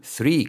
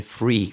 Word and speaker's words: three: Acordaros, no vamos a decir one con G, sino one, two, three, con Z, de zapato, con Z, de Zaragoza, three, ¿ok? Nada de free, con F, ¿ok?